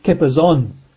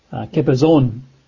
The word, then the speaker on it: chippazon: The word there for haste in the Hebrew, you would pronounce it, I think, kipazon. Kipazon. I'll spell that in English, but it would be spelt in English as C-H-I-P-P-A-Z-O-N, kipazon.